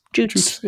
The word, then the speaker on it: Jutes: Wikipedia has it with the IPA, International Phonetic Alphabet, like pronunciation guide, has it with a J as in jam. – Okay. – Jutes.